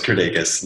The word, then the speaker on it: Kirdeikis: Yeah, pretty close. Kirdeikis, Lithuanian.